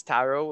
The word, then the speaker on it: Tarot: And it's Tarot with a silent T.